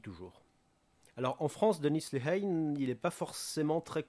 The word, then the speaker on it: Lehane: Alors son nom n'est pas facile à prononcer en français, donc je dis Dennis Lehane. Alors ça s'écrit Denis, donc comme le prénom Denis, mais avec deux N. Et Lehane, ça s'écrit L-E-H-A-N-E.